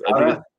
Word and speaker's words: Fauda: I think it's Fauda. So I used to say Fauda, and so, I was corrected by an Israeli.